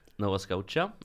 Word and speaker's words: Nova Scotia: Nova Scotia, eller hur man nu uttalar det.